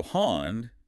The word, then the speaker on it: hond: So hond, dog, with a D sound, gets pronounced with a T sound.